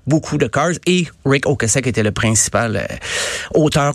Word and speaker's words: Ocasek: Euh, ben lui-même, il disait Rick Ocasek.